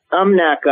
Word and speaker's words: Umnak: How do you pronounce that? Umnak? What is that? – Umnak Island is our headquarters,